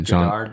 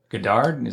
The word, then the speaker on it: Godard: Godard. – Jean? Yeah, what? – Godard? – Yeah.